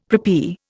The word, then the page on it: preppy: Indian